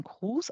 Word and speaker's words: Krewes: Aber das ist für die Crews, also verschiedene Karnevalsgesellschaften aus New Orleans, die nennt man Crews, also Krewes geschrieben, wird also Crew ausgesprochen